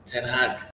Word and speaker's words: ten Hag: Erik ten Hag. Did I say that right? – Yes, ten Hag.